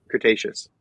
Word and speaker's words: Cretaceous: Cretaceous.